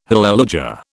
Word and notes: hallelujah: British English

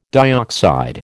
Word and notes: dioxide: American English

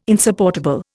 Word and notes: insupportable: Indian

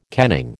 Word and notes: kenning: American English

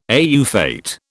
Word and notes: au fait: British English

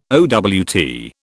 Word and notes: owt: British English

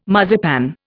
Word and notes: marzipan: Indian

